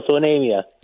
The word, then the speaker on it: Onamia: Let's go to Onamia? Did I say it correctly? Onamia? – Close. – Anemia. – Anemia. I'm sorry.